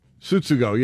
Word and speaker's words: Tsutsugo: Do you pronounce the T in in uh, Japanese or is it? – Is it Tsutsugo? Tsutsugo. Tsutsugo. Yeah, that second suit is emphasized.